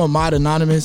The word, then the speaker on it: Ahmad Anonimis: I'm Ahmad Anonimis, so Ahmad, and then Anonimis, I spell it with two I's.